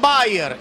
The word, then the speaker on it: Beier: Este se pronuncia igual, pero se escribe distinto al de la aspirina. Maximilian Bayer, este B-E-I-E-R,